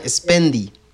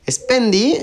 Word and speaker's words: Spendee: Yo otra que, les tengo, esta sería el número 3 de, la lista que, les estamos compartiendo, se llama Spendy. Spendy eh, se escribe eh, S-P-E-N-D-E-E, Spendy.